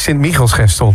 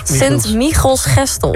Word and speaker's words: Sint-Michielsgestel: Het is Sint-Michielsgestel. De klemtoon ligt op Michiels. – Sint-Michielsgestel.